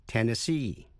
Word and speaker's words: Tennessee: Tennessee, double N, double S, double E, tiểu bang của cựu phó tổng thống Al Gore, nhấn mạnh vào vần cuối C, Tennessee.